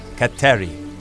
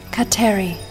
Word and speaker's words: Kateri: In Mohawk, her name is pronounced Kateri. – Kateri.